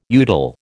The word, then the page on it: udal: American English